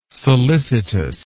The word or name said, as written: solicitous